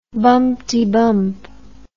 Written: bumpetybump

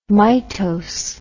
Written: mitose

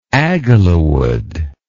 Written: agilawood

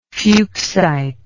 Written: fuchsite

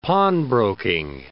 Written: pawnbroking

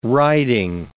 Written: riding

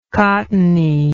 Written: cottony

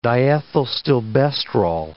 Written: diethylstilbestrol

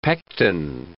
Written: pecten

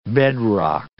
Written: bedrock

